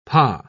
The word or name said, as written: pah